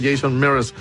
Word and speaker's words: Jason Mraz: Pues es Jason Mraz, pero se pronuncia Jason Mraz, el invitado en especial.